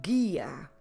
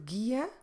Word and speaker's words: guia: Como se escreve guia? G-U-I-A. Gehe. Don't forget. Silent U.